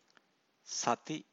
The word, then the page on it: sati: Pali